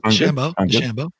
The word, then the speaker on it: DeChambeau: Bryson DeChambeau. Is that right? Is that DeChambeau. Yeah, that's the French-Canadian version.